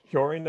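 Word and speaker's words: Hjørring: We should probably mention for listeners that aren't aware, Michaela, you went to Fortuna Hyorin, I believe it's pronounced, for the fall for a loan.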